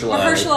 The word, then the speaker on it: Mahershala: Mahershala Ali.